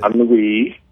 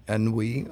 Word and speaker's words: Ennui: I hope I pronounce it right. N U I. Uh, Ennui. Ennui. Okay. – I'll, okay. – I'll remember that from now on.